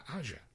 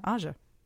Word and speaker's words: Aja: So, well, it's spelled AJA. – Asia. – Yeah, Asia. I'm saying.